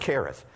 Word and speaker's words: Cherith: I know it's a C-H in the English, but it's not in the Hebrew. It's a K. So it's pronounced Cherith.